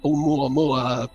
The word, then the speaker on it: Oumuamua: None of these things were seen coming in from deep space like that Oumuamua, whatever. – Yeah. I don't know how to pronounce it either. – No, that's the correct pronunciation.